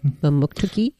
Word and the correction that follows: Mamotokuti: Mamotokuti. – Mamotokuti. Oh, I missed something in there.